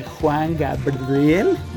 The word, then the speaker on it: Juan Gabriel: de Juan Gabriel, ya lo dije bien, en el Palacio de Bellas Artes.